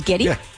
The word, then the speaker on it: Geddy: I just learned that his mom was Polish and his name is Gary, but she pronounced it Geddy.